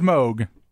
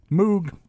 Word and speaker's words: Moog: The guy's name is Moog, but he doesn't care if you call his creation a Moog. – Moeg? – Just the way it is. M-O-O-G. Moog.